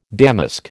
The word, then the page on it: damask: American English